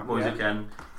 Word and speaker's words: Moise Kean: Moise Kean.